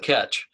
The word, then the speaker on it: Ketch: Well, let's go with Ketch.